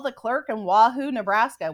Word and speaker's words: Wahoo: And the clerk was like, Wahoo, as in Wahoo, I get to get out of here.